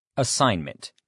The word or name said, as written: assignment